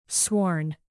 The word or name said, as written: sworn